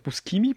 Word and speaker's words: Buscemi: Wie spricht man? Buscemi? – Buscemi.